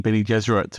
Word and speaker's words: Bene Gesserit: It's well known that Frank Herbert chose the name Bene Gesserit to rhyme with Jesuit.